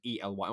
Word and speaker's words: Ely: And in 2021, they raced in a place called Ely, which is E L Y. It might be Eli. I don't know how you say it. – E-L-Y. that's near me. – Ely, there you go.